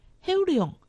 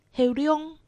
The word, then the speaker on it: 后龙: 苗栗县后龙镇客家话四线腔发音黑龙，海陆腔发音黑龙。